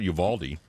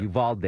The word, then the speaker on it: Uvalde: The way you should pronounce it, Uvalde. Or Uvalde. Uvalde. Or Uvalde. – Yeah. Uvalde. Yeah.